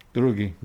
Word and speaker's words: drugi: Drugi. – Drugi. – Drugi. – So it sounds like dragi. – Yeah. Mm-hmm. – It's almost spelled like druggy.